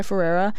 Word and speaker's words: Ferreira: Then we have Everything Is Embarrassing by Sky, how do you pronounce their last name, Sky Ferreira.